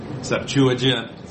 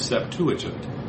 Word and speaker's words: Septuagint: Septuagint, Septuagint, a lot of different ways. So I'll just go, so one pronunciation is Septuagint. It's an easy way to say it, but you'll hear people say Septuagint. Septuagint or Septuagint, there's a lot of different ways to pronounce this word.